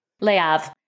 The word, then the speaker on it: Le Havre: Le Havre.